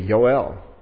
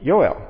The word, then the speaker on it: Yoel: And I should say that we say Joel, for J-O-E-L, we say Joel. A more correct pronunciation that English people will say is Joel. That's why I call our elder Joel, because he likes to go by that pronunciation. And actually, if you were in Israel, it'd be Yoel, because there's no J. So it'd be Yoel.